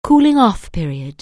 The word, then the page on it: cooling-off period: anglais